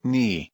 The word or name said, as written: knee